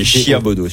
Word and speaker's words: Chiabodo: Quand tu écris Chiabodo, c'est H-I-A-B-O-D-O. – Non, c'est Chiabodo. – Comme ça se prononce.